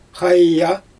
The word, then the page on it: xaja: Xyzyl